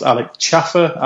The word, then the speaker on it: Schaefer: Alex, if you're listening, I do know it's pronounced Alex Schaefer after last night. Well, he's English and everyone thinks he's German, so they put an S on it and shove an umlaut in the middle.